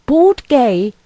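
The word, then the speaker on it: Bord Gáis: Oh, it's probably the way they spell it. Bord Gay Energy Theatre Dublin, it sounds like. Spelt B-O-R-D-G-A-I-S. Don't know.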